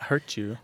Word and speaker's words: hurtu: Hurtu? – I know. It sort of rhymes with virtue, but hurtu is if you have a bruise or a cut or something.